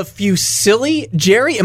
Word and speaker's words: Fusilli Jerry: Okay, so next week we've got season 6, episode 20, The Fusilli Jerry. Am I pronouncing that right? – Yes.